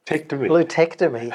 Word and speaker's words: LuTectomy: So maybe we'll start with the one you found difficult to pronounce. What was it called? – Lutectomy. – Lutectomy. That's right.